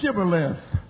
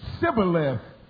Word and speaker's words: Shibboleth: And they said unto them, Say now Shibboleth. And they said, Sibboleth, because they could not frame to pronounce it right.